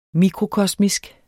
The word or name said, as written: mikrokosmisk